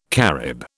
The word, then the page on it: Carib: British English